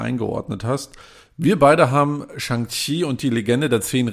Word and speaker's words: Shang-Chi: Und wenn ich den Filmtitel lese, würde ich sagen, es heißt Shang-Chi und nicht Shang-Chi, weil es ist am Ende C-H-I und nicht Q-I.